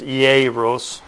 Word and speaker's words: Iairos: And behold, there came a man named and we pronounce it in English the Jairus, but it's in the Greek it's Iairos.